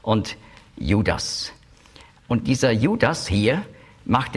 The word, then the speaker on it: Judas: Judas ist die griechische Aussprache von Judah, beziehungsweise Jehuda. Und zwar macht man eben noch ein S am Schluss, weil für griechische Ohren Judah, das klingt weiblich. Aber mit dem S ist es männlich.